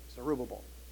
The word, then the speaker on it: Zerubbabel: You probably don't know them, except maybe Zerubbabel. Pronounce that. Zerubbabel.